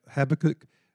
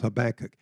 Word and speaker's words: Habakkuk: Some of you, I think, pronounce that Habakkuk, but my dictionary says Habakkuk.